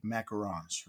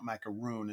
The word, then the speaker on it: macaron: This last weekend, I was so mad because on Friday, you know, at the Jolly Holiday, they have those Mickey Mouse-shaped raspberry macarons, right? – Yeah. – I think I'm pronouncing that correctly. Macaron. It's not macaroon. It's macaron.